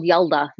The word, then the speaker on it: Yalda: And then they have what this is called Yadal. I think it's how you pronounce it. – Sounds like a Star Wars character. – Oh my God. It's called Yelda, not Yadal. Yelda.